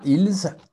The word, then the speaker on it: ils: OK, so the S is silent, very well. So now, ils.